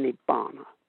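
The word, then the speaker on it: nibbāna: And that word in Sanskrit is nirvana, N-I-R-V-A-N-A. Pali pronunciation, nibbana.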